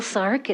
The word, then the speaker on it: Sark: My name is Jill Sark. It's spelled S-A-R-K.